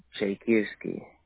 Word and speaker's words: Chaikivskyi: No, V-O-L-O-D-Y-M-Y-R. – Wow, that's very unique. Okay. And what's your last name? – Chaikirsky. – Ooh, okay. Can you spell that? – C-H-A-I-K-I-V-S-K-Y-I.